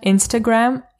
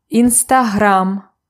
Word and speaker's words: Instagram: When you say the name of the social network in Ukrainian, pronounce it in Ukrainian way. So it's not Instagram, it's Instagram.